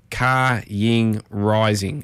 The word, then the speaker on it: Ka Ying Rising: If you're wondering how it's pronounced, it's spelled Ka Ying Rising, so K A, then Y I N G, then Rising, so Ka Ying Rising.